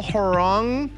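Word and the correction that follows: Hornung: Probably pronounced that wrong. You know, yes, Paul Horning.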